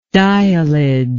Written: diallage

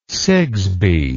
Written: sigsbee

